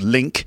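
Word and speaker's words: LingQ: That's L-I-N-G-Q, pronounced Link.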